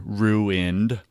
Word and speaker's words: ruin: financial ruin, my wife corrects me, ruin, it's two syllables, honey, two syllables.